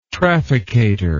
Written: trafficator

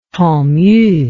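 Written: tantony pig

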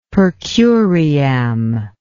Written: per curiam

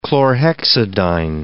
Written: chlorhexidine

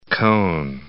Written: Kohn

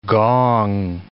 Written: gong

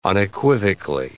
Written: unequivocally

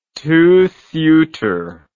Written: two suiter

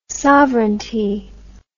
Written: sovranty

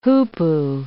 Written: hoopoe

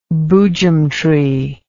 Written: boojum tree

